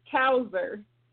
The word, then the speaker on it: Cousar: I hope I'm pronouncing the last name correctly. Cousar, is that it? Tequila? – It's Cousar.